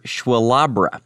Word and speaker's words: SWLABR: Before that, Cream, with one of the most difficult song titles to pronounce. You heard Schwalabra. Or you could spell it out, S-W-L-A-B-R.